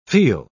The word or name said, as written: feel